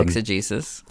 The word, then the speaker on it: exegesis: I don't even know if I'm saying that right. – Yeah, exegesis.